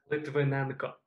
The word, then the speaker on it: Lytvynenko: Nope, not it. – Well, how do I pronounce it? – Lytvynenko.